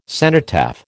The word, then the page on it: cenotaph: American English